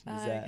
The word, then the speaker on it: Nazaire: This is French. Nazaire. – Okay, Nazaire, yeah.